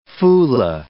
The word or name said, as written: Fulah